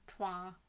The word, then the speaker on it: toile: No, it's toile. It's French. – Isn't there an L in there? – Yeah, but I guess you don't.